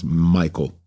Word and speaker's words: Michael: and their brethren in their father's house, Michal, some of us would pronounce Michael, Meshulam, Sheba, Jerai, Jachin, Zia, and Eber, seven in all.